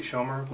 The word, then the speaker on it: Schomer: My name is Attorney Jesse Schomer. Last name is spelled S-C-H-O-M-E-R.